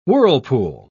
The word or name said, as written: whirlpool